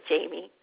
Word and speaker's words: Jaime: And so I was called Jaime a lot growing up, and people sometimes think that that my name is Jaime. And so there's that part in there that says it's actually for the French, which is I love, and it's Jamie. But I pronounce it Jamie.